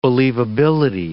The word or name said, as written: believability